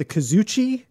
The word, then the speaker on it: kazoochi: And, so you call it the, am I saying this right? The kazoochi? – Yeah.